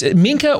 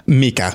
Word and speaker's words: Mika: I want to watch the very beginning again as it's Minka or Mika? – Mika.